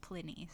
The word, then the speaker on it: Pliny: And then my friend Maddie, who did their degree in like classical studies and knows Greek, told me that it would most likely be pronounced Pliny. So now we know.